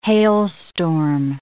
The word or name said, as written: hailstorm